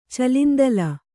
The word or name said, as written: ಚಲಿಂದಲ